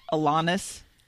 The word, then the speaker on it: Alanis: That's Alanis. Alanis Morissette. – Alanis. – Rhymes with Adonis.